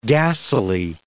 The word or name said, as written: gassily